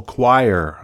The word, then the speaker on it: choir: A gospel choir. A gospel choir is a type of music sung by a choir. Now, this is beautiful English here. Choir. C-H-O-I-R. Choir. Pronunciation choir. That is a group of people who sing together.